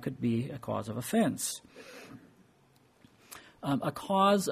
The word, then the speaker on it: offense: The word also can be used in another way, and that is offense. When you put the emphasis on the first syllable, if you can remember how that works.